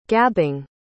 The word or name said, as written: gabbing